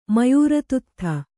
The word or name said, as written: ಮಯೂರತುತ್ಥ